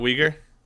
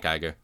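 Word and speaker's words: Uygur: Well, is that how you pronounce it? Not Uyghur? – Yeah, it's Cenk Aygar.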